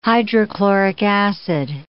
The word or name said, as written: hydrochloric acid